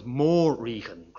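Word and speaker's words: Morrígan: Now, the first syllable of that word is originally short, but in Middle Irish, perhaps encouraged by folk etymology, the first syllable becomes Morrighan, great queen, instead of something like nightmarish queen.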